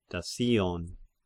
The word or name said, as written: Dassion